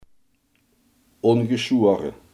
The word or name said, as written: ongeschoren